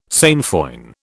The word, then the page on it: sainfoin: British English